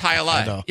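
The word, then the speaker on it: jai alai: But it's pronounced hi-a-lai.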